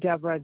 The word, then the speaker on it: Deborah: Deborah, D-E-B-O-R-A-H. Last name is W-A, S as in Sam, E-N-H-O-V-E. – That was W-A-S-S-E-N-H-O-V-E? – Yes.